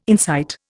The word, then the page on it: insight: Indian